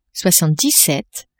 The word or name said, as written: soixante-dix-sept